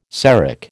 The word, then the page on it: Seric: American English